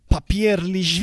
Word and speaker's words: Papierli: Papierli A ver si lo digo bien. Papierli así, así, sí, así es.